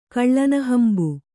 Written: ಕಳ್ಳನಹಂಬು

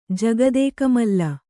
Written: ಜಗದೇಕಮಲ್ಲ

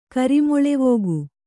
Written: ಕರಿಮೊಳೆವೋಗು

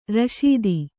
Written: ರಶೀತಿ

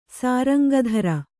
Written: ಸಾರಂಗಧರ

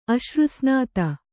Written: ಅಶ್ರುಸ್ನಾತ

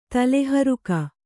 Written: ತಲೆಹರಿಗೆ

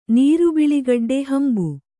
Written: ಹಂಬೆ

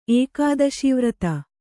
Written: ಏಕಾದಶೀವ್ರತ

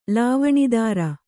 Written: ಲಾವಣಿದಾರ

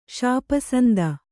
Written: ಷಾಪಸಂದ

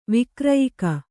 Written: ವೈಕ್ರೀಯಕ

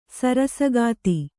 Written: ಸರಸೋಕ್ತಿ

